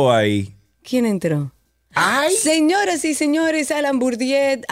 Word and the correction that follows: Bourdieu: acaba, Bourdieu, oh. que no sé pronunciarlo nunca, acaba de entrar a YouTube, eh, tiene ropa, así que no se alarmen, tiene ropa.